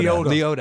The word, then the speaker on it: Liotta: Liotta. – Liotta. – I'll let you say. – It's one of those. It seems to people pronounce it both ways, but it is Liotta.